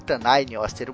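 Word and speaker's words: Tanngnjóstr: Porque a gente fez mais ACC, lá um audiodrama, né, entre o Hércules e o Thor, e eu tive que falar Tanainioster umas 20 vezes. Então eu aprendi. Mas o nome do Tanainioster se escreve assim, ó, Tanginjoster.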